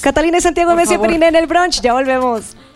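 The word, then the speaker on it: Monsieur Periné: Messier Periné.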